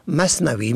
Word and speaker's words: مصنوعی: و از آن گذشته در نوشتار درست مصنوعی یعنی با میم صاد نون واو عین و یا نوشته شده، و اما در تلفظ آن را مصنوعی میخوانند.